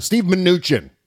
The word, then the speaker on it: Mnuchin: No, it's not Steve Mnuchin. Steve Mnuchin. That's how you pronounce the guy's name.